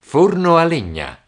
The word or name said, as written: forno a legna